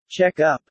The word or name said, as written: check-up